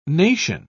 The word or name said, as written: nation